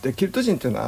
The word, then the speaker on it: ケルト: CELTIC ですかセルティックねこれがねケルトって読む